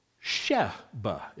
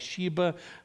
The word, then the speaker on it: Sheba: There was a troublemaker, a son of Belial named Sheba. It's a short E in the original Hebrew. I know it looks like Sheba. It's not how it's pronounced in Hebrew, although if you say Sheba, that's fine. But this is a guy, not a gal. This is not the queen of Sheba. It's Sheba. The son of Bichri, a Benjamite.